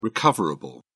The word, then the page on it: recoverable: British English